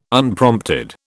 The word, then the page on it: Unprompted: British English